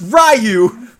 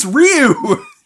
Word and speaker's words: Ryu: It is not pronounced Ryu! Fuck you! Fuck this movie! – Wow, such a visceral response. – It's not Ryu! It's Ryu! He's Japanese! No wonder this movie failed.